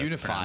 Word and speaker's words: Unifier: All right, so the next one here is Atraxa Grand Unificer. – It has to be how that's Unifier.